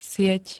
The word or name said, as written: sieť